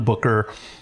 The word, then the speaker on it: Bucher: It's pronounced Booker, but it's spelled B-U-C-H-E-R. My ancestors did me no favor with that. It's been one of the banes of my existence. So, it's John Booker.